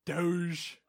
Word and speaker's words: Doge: Soft G. – Yeah. – Doge.